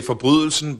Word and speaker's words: Forbrydelsen: A wracając do Danii, wspomniałeś serial Most nad Sundem, było też Dochodzenie, Forbrydelsen, jeśli dobrze wymawiam. – No prawie. – We would say Forbrydelsen, but it's the same word.